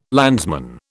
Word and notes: landsman: British English

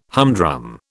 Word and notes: humdrum: British English

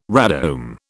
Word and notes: radome: British English

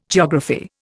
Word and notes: geography: Indian

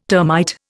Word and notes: termite: Indian

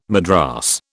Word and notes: madras: British English

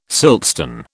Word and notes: siltstone: British English

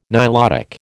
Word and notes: Nilotic: American English

